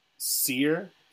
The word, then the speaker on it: Saor: I think it's pronounced Seer. It's Saor. It's a Scottish word. It's like a Scottish Gaelic word for free.